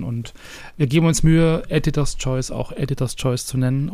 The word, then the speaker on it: Editor's Choice: Editor's Choice.